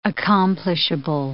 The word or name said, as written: accomplishable